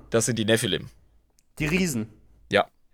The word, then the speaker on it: Nephilim: Nee, Nephilim so geschrieben äh, und so ausgesprochen gibt es in der Bibel.